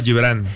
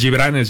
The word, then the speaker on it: Gibran: Y justo mi nombre pues se escribe con G, Gibran. Pero pues mis papás dicen que es Gibran, ¿no? – Ellos, ¿y de dónde es Araige?